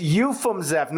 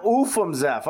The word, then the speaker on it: Ufimzeff: Gotta get rid of the ooh. – I got it. I sound too Canadian. – Yeah, exactly. – That's okay. It's Ufimzeff, not Ufimzeff. Okay.